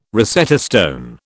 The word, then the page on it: Rosetta stone: British English